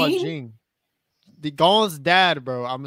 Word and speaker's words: Ging: It is spelled with G-I-N-G, but they don't pronounce the G at the end, so. – Jing. – You're over here saying Jing. Like J-I-N-G.